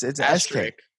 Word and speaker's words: asterisk: No, it has an SK. – Asterisk. – But I don't think you say the SK. – It's a silent S. – Asterisk. Yeah. Asterisk. – No, it's not. Asterisk. I'm looking on. I Googled it right now. – Asterisk. – And it's asterisk. There's an asterisk. It's like risk, but it's asterisk.